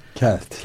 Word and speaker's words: Kelt: O da İngilizlerin ünlü Selt değil mi? Selt mi? Kelt mi? – Kelt. – Kelt.